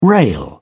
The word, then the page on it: rail: British English